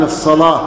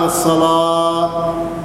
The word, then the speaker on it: الصلاة: كذلك هناك من لا ينطق بالهاء من الصلاة، فيقول حي على الصلاة، الصلاة هي النار، احنا هاربين من النار وتدعنا النار، فلا بد من إظهار الهاء، لأن الهاء من حروف الهمس وهو خفيف ضعيف فيحتاج إلى قوة، فلا بد أن يقول حي على الصلاة.